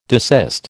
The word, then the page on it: desist: American English